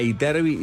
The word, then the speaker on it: Ytterby: Aprovechá esta oportunidad, por las dudas, Jack, ya saben cómo se escribe, pues es una marca célebre, pero el modelo suena Iterbi y se escribe con Y al principio y al final, doble T y B larga, Y-T-T-E-R-B-Y.